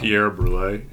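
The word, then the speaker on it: Pierre Brûlée: titled Pierre Brulez. I don't know. – Pierre Brulé, yeah. – I don't know when the S is silent and when it's not.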